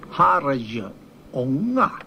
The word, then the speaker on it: Herrljunga: Herrljunga. – Herrljunga. Hör ni skillnaden? Inte bara mellan herr och harj, utan hör avstavningen. Harjunga. J-ljudet hör till första stavelsen.